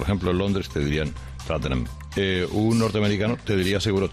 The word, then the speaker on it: Tottenham: En la pronunciación British sí. Es más Tottenham. Con esa O hecha A, que es más americano que British en realidad.